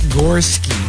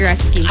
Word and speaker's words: Goreski: I always thought it was like Gorski. – Oh, no, Brad Goreski.